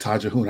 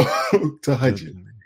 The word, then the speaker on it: Tahajjud: I called it Tajahun. I didn't know how to pronounce it. So Tahajjud. – Tahajjud, yes. The night prayer.